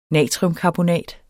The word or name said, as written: natriumkarbonat